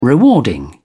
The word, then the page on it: rewarding: British English